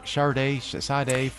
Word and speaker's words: Sade: Nilufer Yana, Y-A-N-Y-A, that track is Baby. And there's, what, is it, am I saying her name right? Sade? – Yes, I totally can make that connection.